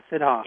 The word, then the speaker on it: Siddha: And you're called, I can't pronounce, it's Siddha, Shita? It's Siddha, yeah, Siddha.